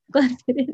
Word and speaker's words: Glenfiddich: Glenfiddich.